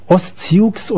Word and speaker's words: Ostsiux: Und da hätten wir nämlich Marinas alte Band Ostsiux, oder eigentlich Sus heißt es ja, aber Ostsiux. – Eigentlich schon, aber da es alle Ostler waren, die alle so aussprechen, wie man das schreibt.